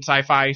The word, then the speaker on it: Syfy: but you can copyright or trademark the phrase S-Y-F-Y. – Right. – And just pronounce it Sci-Fi.